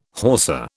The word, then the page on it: Hausa: British English